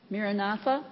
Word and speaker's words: Maranatha: How do you pronounce the name? – Say it again. Maranatha? So I had the accent on the wrong syllable.